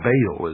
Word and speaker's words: Baal: For this is the only ancient portion, the beginning of the ancient worship of Baal, or Baal as some pronounce it.